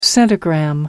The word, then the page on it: centigram: American English